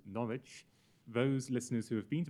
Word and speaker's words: Norwich: So, Norwich. – Norwich. – Yes. – Oh. First time it sounds like knowledge, you know, someone who has knowledge.